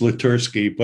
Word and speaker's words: Letersky: Actually, it's Letersky, but I answered to anything that's close.